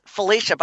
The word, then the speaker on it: Fylicia: Yay, that's so interesting because my name is Lisa Wysocki, but my legal first name is Fylicia, but I spell it F-E-L, and I couldn't pronounce it till I was 7, but I've never seen it spelled your way.